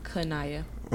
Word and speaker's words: Qineya: My name is Qineya.